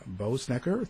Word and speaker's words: Boessenecker: And with us is the author of the book, John Boessenecker.